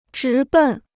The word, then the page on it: 直奔: zhí bèn